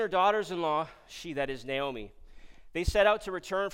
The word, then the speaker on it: Naomi: I mispronounced her name last Sunday, and I know some of you maybe lost sleep over that, but I repent, and I'm going to say Naomi. Naomi. Not Naomi, Naomi.